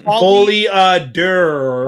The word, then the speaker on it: Folie à Deux: Folie à Deux.